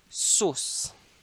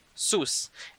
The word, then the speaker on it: sus: Now the word is sus. Sus. It's spelled as S-U-S. Sus. Almost like sus in American English.